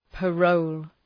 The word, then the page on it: parole: {pə’rəʋl}